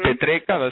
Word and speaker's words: Petreca: Petreca, that's my last name.